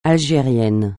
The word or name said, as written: algérien